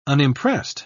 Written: unimpressed

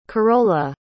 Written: karola

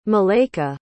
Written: malaika

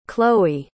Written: chloe

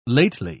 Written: lately

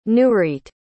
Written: nureet

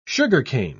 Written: sugarcane

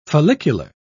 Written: follicular

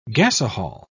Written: gasohol

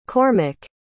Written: Cormack

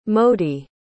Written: modi

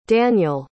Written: daniele

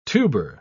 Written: tuber

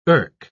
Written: irk

